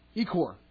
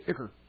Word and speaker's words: ichor: Vampire blood is called ichor. Actually, yep. They pronounce it ichor. Ichor.